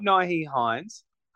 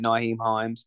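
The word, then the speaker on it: Nyheim Hines: I think it's not Naeem Hines. I think they spent too much on James Cook to let Naeem Hines just I can't say his name properly.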